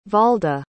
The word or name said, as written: valda